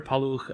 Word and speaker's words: Paluch: My name is Peter Paluch. On Twitter, I am at Peter underscore P-A-L-U-C-H, since my last name is difficult to pronounce for somebody who's not Slovak.